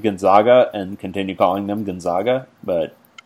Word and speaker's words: Gonzaga: But the thing with Gonzaga, and there are so many people like situated in Eastern Washington that are spitting mad that we're not calling them Gonzaga. So many people. Because that's how you're apparently supposed to pronounce it.